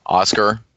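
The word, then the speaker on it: Huascar: I believe it's pronounced Oscar, but it looks like Hua Scar, like we talked about with the Pacino reference there.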